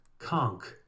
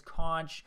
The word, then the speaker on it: conch: conk. – Conk. I learned how to say the word conk, and I realized that since I was a child, I've been saying the word as conch like a moron, and now I'm glad that it's now conk.